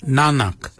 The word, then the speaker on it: nangag: So for neck we say nanak, nanak. Nanak is spelled N-A-N-G-A-G. Nanak. Nanak is your neck. Chin. We say yet. Yet for chin. Yet is spelled Y-E-D.